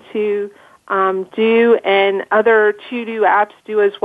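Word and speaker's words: TeuxDeux: Another tool that I would highly recommend, and it's a really cool tool, it's something that's a little different, and it's called To Do, but it's spelled the French way, so it's T E U X D E U X.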